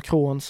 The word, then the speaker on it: Crohns: Han har ju den här kroniska tarmsjukdomen Krons eller Crohns som man uttalar det.